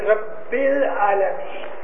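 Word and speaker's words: Rabbil Alameen (ربّ العالمين): Now here, the pronunciation is Rabbil Alameen.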